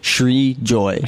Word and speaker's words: Srijoy: Srijoy. Srijoy.